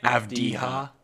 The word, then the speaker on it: Avdija: Avdija. – Avdija. Sorry. I don't know. It's gut feeling that I said it like 5 seconds before you did. – I've got whatever. – Avdija.